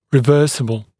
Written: reversible

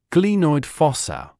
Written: glenoid fossa

